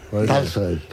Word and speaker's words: Tolstoy: Tolstoy. – Porque las palabras que se escriben en O en ruso, pero van acentuadas, se pronuncian casi A.